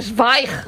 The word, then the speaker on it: Zweig: Tú sabes que yo toda mi vida he estado diciendo que yo leía Stephen Zweig. – Ah, claro. Es no. Zweig. – Zweig.